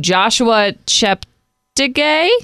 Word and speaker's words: Joshua Cheptegei: A 23-year-old Ugandan runner, I might butcher his name, named Joshua Cheptegei.